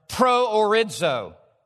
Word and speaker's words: Proorizo: I'm going to pronounce it because you're going to hear an English word in it. It's a compound word. Proorizo.